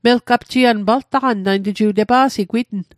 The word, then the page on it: paltaqan: Mi'gmaq